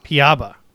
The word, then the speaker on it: Piaba: So for today's podcast, I'm super excited to introduce is it Project Piaba? Is that how you pronounce it? We've been pronouncing it all different ways. – Piaba. – Excellent. – That's it. Yep.